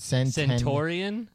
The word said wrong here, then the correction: centenarian: No. – Centennial? – Centenarian. – Centenarian.